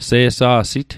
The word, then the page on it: se'sa'sit: Mi'gmaq